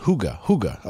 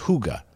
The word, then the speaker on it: Hygge: The Danish word Hygge, pronounced Hygge. Okay. It's pronounced Hygge.